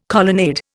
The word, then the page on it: colonnade: Indian